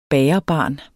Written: bagerbarn

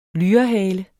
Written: lyrehale

